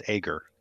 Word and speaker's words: Ager: Is that how you say his name, or is it Ager? Okay. – Ager. – Ager. Say it again. – I believe it's Ager. – Oh, okay. – Hard G.